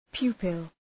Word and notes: pupil: ‘pju:pəl

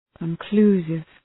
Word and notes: conclusive: {kən’klu:sıv}

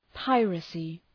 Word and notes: piracy: ‘paırækı

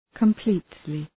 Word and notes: completely: kəm’pli:tlı

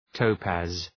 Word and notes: topaz: ‘təʋpæz

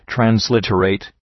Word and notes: transliterate: trænz’lıtə,reıt; træns’lıtə,reıt

